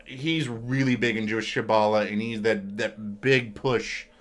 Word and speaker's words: Kabbalah: Okay, with the birth of Kabbalah, in Jewish Kabbalahs, I can't pronounce that. Kabbalahism? – Kabbalah. – Did I say that right? – I believe that's how you say it.